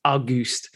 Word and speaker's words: Auguste: When you look at it, I know you probably pronounce it as Auguste or Auguste. – Yeah, it looks like August with an E. That's what it is, August with an E. But Auguste. – The way you pronounce it is Auguste.